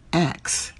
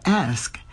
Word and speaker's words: ask: I remember a radio intern once was reading copy for a news story and she kept pronouncing X for ask. I said, no, say basketball. She said, basketball. I said, good. Now say bask. She said, bask. I said, great. Now drop the B and say the word. She smiled and said, X.